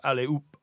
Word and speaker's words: allez-oop: It comes from H-O-P, op. It sounds like op in French, because you don't pronounce the H. So allez-oop, allez-oop, like that. So it sounds like up.